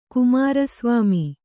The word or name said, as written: ಕುಮಾರಸ್ವಾಮಿ